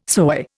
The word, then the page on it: soy: Indian